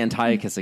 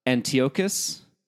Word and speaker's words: Antiochus: See, I'm going to say Antiochus again because I want to. But it's so Michael wrote that it's pronounced Antiochus.